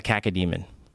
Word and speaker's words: cacodemon: Every time you murder a cacodemon, which is those flying balls, you pull out their eyeball. – What is it called? A caca? – A cacodemon. – A caca? What's the... Spell it. – C-A-C... cac-o... Oh, C-A-C-O...